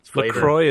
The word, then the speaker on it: LaCroix: But now I'm drinking, I don't know how you pronounce this, LaCroix? What are those bubbly waters? – LaCroix is what we say. – Okay, that sounds better. – Yeah. I call it LaCroix just to be an idiot. – LaCroix.